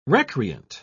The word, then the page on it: recreant: British English